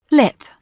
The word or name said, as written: lip